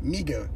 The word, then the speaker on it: Mego: No, I actually, when I was a little kid, the toy of the Mago Spider-Man, actually properly pronounced Mego Spider-Man. – Is that the way you say it? – That's how you're supposed to say it. – Really? – It's Mego.